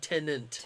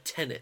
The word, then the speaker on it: Tenet: I loved it a lot more than Tenet. – Yes, Tenet was... – Tenet. I keep calling it... It sounds like I'm saying Tenant. – Tenant. – Tenet. – Tenet. Yeah.